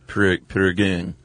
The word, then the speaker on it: Prägung: Like an A with a neumat, sort of a U. Prägung.